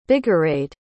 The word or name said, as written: bigarade